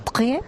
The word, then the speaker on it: Chatrier: Bueno, está Rafa Nadal jugando contra el australiano Jordan Thompson en la pista grande, la Philippe Chatrier. ¿Y cómo va ese partido? Mucho Ángel García Muñiz. – Pero repíteme lo de Chatrier, por favor. – Chatrier.